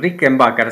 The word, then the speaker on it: Rickenbacker: Y para demostrarte cuál es la Rickenbacker, le, perdón, Rickenbacker se pronuncia correctamente, yo tengo una acá, mirá, no la podés ver, ahora la vas a escuchar.